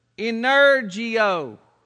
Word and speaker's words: energeo: But in the Greek language, it's the word energio. What's that sound like? It's spelled like this, E-N-E-R-G-E-O.